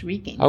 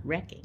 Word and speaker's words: wreaking: Well, it's got an A in it, not a C. It's not wrecking.